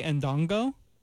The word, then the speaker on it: Ndongo: Some even more high-profile players are Bay Ndongo. I hope I'm pronouncing that correctly. – It sounds right.